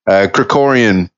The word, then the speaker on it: Krikorian: Serge Krikorian, and I'm glad that you pronounced it because I was working on the enunciation, the word Krikorian, and I hope I'm pronouncing it properly now. – You got it.